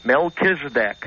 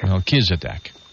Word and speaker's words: Melchizedek: Melchizedek. – Melchizedek.